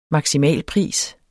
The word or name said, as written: maksimalpris